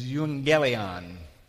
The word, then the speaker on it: euangelion: Now, the other word is euangelion. That's how that's pronounced.